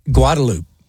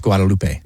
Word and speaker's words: Guadalupe: And this one's pretty funny because Guadalupe is what we say here. I know it should be pronounced Guadalupe. – Yeah. – It is not. – Not here. – Even by Hispanics in Austin, they say Guadalupe when they're referring to the street.